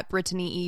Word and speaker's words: Brittany: I don't normally say my name like that. I do it so that you know how it's spelled, hopefully, in the pronunciation. I promise I don't demand that people say it that Brittany.